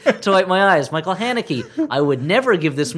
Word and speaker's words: Haneke: Haneke. – That's close enough. – No, it is not at all. One sounds like Hanky, and one sounds like Haneke.